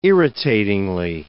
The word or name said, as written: irritatingly